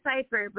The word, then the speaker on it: Seifert: It's actually Seifert, but don't worry, everyone gets it wrong.